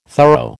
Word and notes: thorough: American English

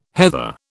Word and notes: heather: British English

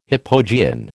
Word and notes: hypogean: American English

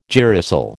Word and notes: girasol: American English

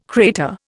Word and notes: crater: Indian

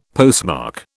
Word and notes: postmark: British English

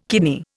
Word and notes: kidney: Indian

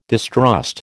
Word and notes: distrust: American English

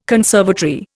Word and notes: conservatory: Indian

